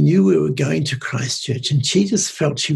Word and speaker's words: Christchurch: Can you Christchurch.